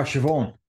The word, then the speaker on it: Siobhan: And third place was uh, Sobian Sefton in 36.57. I'm sure that's an Irish name that I've pronounced that wrong. – Oh, Siobhan.